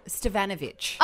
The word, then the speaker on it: Stevanovic: Is that how I say her name? – Stefanovic. Stef- no, no St- Stevanovic. Ah, there you go. – Because it's the emphasis that wasn't like Karl Stefanovic's last name, because that's been Aussieified.